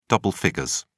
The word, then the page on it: double figures: British English